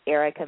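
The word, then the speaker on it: Erika: This is Erica McKay, and if you'd like to reach out to me, you can do so on Facebook.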